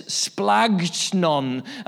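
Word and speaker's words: splagnon (σπλάγχνον): For those taking notes, the Greek noun for compassion, it sounds a bit more like a German word. It's splagnon. And if you're to spell it phonetically, it goes like this: splagchnon.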